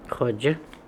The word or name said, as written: Hodja